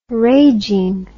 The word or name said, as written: raging